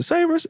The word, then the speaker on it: Sabers: Is it Sabres? S-A-B-E-R-S? – Yeah, that's how I would Sabres.